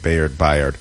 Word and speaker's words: Byard: Byard. I was confused how to say it. Bayard.